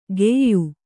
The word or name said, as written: ಗೆಯ್ಯು